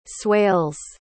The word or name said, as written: swales